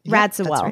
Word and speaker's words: Radziwill: How do we say that name? This is Jackie O's Radziwill.